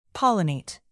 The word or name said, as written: pollinate